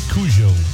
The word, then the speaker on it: Kodjoe: Boris, how do you say that? K-O-D-J-O-E. Cujo. – Yeah.